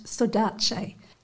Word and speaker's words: saudade: And it's also a translation of a Portuguese word that, and I'll try and pronounce it properly, I believe it's pronounced saudade.